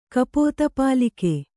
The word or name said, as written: ಕಪೋತಪಾಲಿಕೆ